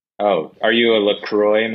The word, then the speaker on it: LaCroix: Apparently it's LaCroix. – You'll never make it in Hollywood if you keep calling it by the French pronunciation.